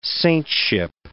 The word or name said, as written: saintship